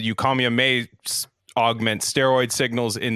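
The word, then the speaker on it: Eucommia: It's mostly eucomia. Is that how you say it? – Eucomia. Yeah.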